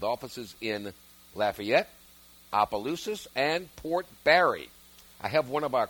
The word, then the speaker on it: Barre: Port Barry, even though it's spelled B-A-R-R-E. Port Barry.